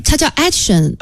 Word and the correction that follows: Edition XV: Edition XV。 – 哦，还是你发音标准。